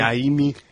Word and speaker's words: Najimy: Kathy Najimy? – I forget how to pronounce her name. – Naimi.